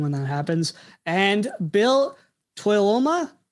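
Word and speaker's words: Tuiloma: Toyoloma is kind of the way I've heard it pronounced. So that was close enough, you know.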